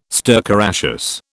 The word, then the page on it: stercoraceous: British English